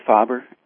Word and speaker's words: Faber: Is it Faber or Faber? How do you say it? – We say Faber.